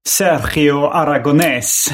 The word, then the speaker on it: Sergio Aragonés: Ja, Sergio Argonnes sa jag som liten. Nu gissar jag på att han uttalade Sergio Aragonés.